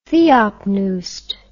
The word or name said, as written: theopneust